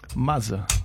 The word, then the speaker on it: Mazza: Mazza. – How do you spell that? – M-A-Z-Z-A.